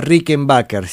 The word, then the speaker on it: Rickenbacker: este, se pronuncia Rickenbacker, sí, perdón, este, me corrijo, me corrijo. Acá me corrige Gabriel porque tenemos un amigo en común, Juan, este, que siempre dice, se dice Rickenbacker porque no es este, de origen inglés, sino que es alemán y toda la historia.